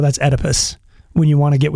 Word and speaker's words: Oedipus: It's Oedipus. You didn't Oedipus. – Pronounce the O.